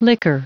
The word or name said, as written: liquor